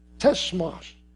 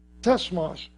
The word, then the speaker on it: tsemach: The branch, that's from a word, a Greek or a Hebrew word, it's spelled T-S-E-M-A-C-H, tesmash, tesmash, which means branch, which is the sprouting one.